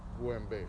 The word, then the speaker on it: Guembe: Is, but it's pronounced with G, Guembe.